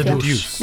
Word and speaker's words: The Deuce: creador de The Wire y Deutsche. A ver, no sé ni cómo se pronuncia. – The Deuce. The Deuce.